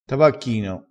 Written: tabacchino

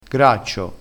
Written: graccio

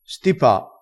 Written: stipa

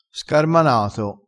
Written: scarmanato

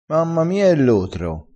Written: lotro

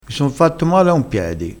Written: piedi

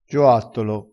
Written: gioattolo

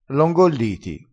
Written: ingolli

